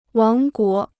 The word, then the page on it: 王国: wáng guó